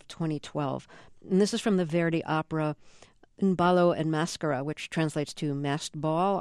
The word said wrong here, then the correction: Un ballo in maschera: I'll do it slowly. Un ballo in maschera. – Un ballo in maschera. No, that was terrible. – No, that's okay. Say maschera. – Maschera. – Now say maschera. – Maschera. – But you want the accent on the ma. Maschera. – Maschera. – That's right.